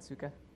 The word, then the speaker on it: Otsuka: Uh, I just got the press release this morning, and I was wondering about tomorrow's um, meeting with Otsuka. How you pronounce Otsuka. That? Otsuka.